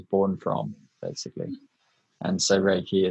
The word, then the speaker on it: Reiki: I was going to ask you actually, what is Reiki? Is it how you pronounce it? – Yeah, Reiki. It's a meditation technique.